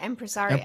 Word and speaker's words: Impresaria: She hosts a highly curated roundtable business networking brunch called the, how do you pronounce that? Impresaria? – Yeah, Impresaria.